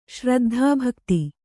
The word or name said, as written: ಶ್ರಾದ್ಧಭೋಕ್ತø